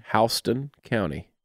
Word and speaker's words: Houston: The name of that county is spelled the exact same way as Houston. But that's not how you pronounce it. – Houston? – Houston County. – Houston County.